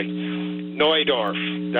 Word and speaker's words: Neudorf: Like, for example, I think you mentioned the place east, northeast of Regina, Neudorf, that would be the proper pronunciation, but somehow it's wandered into Neudorf. Well, it's not spelt N-E-W, it's spelt N-E-U.